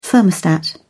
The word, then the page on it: thermostat: British English